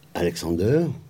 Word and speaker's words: Alexander: Ils auront comme enfant euh, Alexander, c'est comme ça qu'on prononce, on, lui donnait son prénom allemand.